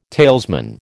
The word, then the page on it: talesman: American English